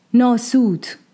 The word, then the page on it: Nāsūt: Ādamians Realm